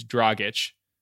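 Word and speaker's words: Dragić: First of all, I know the right way to say it. It's Dragic. Okay.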